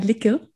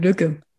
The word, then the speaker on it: Lücke: Ja, aber viele sagen Licke, also Lia Licke. Aber ich würde es persönlich mit einem Ü, also mit so einem Ü-Ö aussprechen, Lücke.